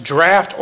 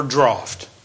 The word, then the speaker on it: draught: And I looked it up, and it's pronounced draft or draught. Draft is the American pronunciation. Draught is the British.